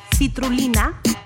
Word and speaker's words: citrulina: Apparently, la sandía contiene un ingrediente llamado citrulina.